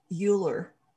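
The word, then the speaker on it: iolair: And I drew the card of the eagle, which in Gaelic, let's see if I can say this, which in Gaelic is pronounced Euler.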